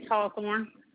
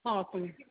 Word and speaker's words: Hawthorne: Patrice Hawthorne. – Okay, one moment, I'm sorry. Okay, you said Patrice Hawthorne. – Can you spell that for me? – H-A-W-T-H-O-R-N-E.